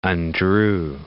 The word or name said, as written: undrew